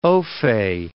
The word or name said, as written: ofay